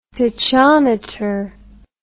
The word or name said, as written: pitchometer